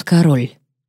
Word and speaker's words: Кэрол: Здесь, во Франции, меня называют король. Король. Ударение на последнем слоге.